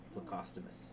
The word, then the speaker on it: Plecostomus: They're known as Plecostomus. – One more time? Postumus? – Plecostomus. – Pocostomus. – Plecostomus. – Plecostomus.